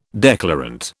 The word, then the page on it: declarant: British English